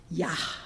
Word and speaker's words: Yah: You're supposed to actually pronounce that H sound at the end. Yah.